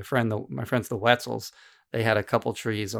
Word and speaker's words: Wetzel: The Wetzels. – The Wetzels, yeah. – And, like Wetzel's uh, Pretzels. Like like yeah, rhymes with pretzels.